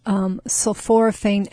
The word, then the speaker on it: sulforaphane: And so the sulforaphane, is that how you pronounce it? – Sulforaphane.